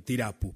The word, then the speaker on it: Tirapú: ¿Le pongo acento en la U o así está bien? Así está bien, Tirapú, perfecto.